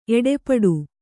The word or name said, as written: ಏಟುಪಟ್ಟು